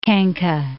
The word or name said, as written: Khanka